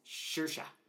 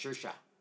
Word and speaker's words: Saoirse: Saoirse. – Saoirse? – Saoirse. As far as I understand, why can't they spell it like Shersha?